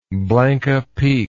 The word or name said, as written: Blanca Peak